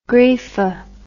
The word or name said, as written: greafa